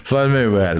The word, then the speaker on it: Floyd Mayweather: Floyd Mayweather. Pronunciei correto, cara? Eu tô meio de... Floyd Mayweather, exatamente, tô certo.